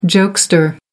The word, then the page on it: jokester: American English